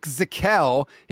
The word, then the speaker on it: Zakelj: That is Nick Zakelj. He has a silent J at the end of his name.